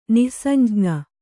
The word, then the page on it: ನಿಃಸಂಜ್ಞ: Kannada